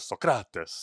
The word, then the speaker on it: Socrates: And then even just, like, you get the actual real pronunciation of Socrates, which is Socrates, you know?